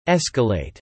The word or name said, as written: escalate